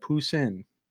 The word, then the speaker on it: Poussin: I went to Google, typed in how do you pronounce, and I typed in that word. And it said Poussin.